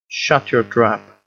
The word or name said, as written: shut your trap